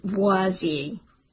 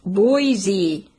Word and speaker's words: Boise: As usual, he pronounced it Boise. "Wrong side." "It's pronounced Boise," Garrett said angrily after he'd caught his breath.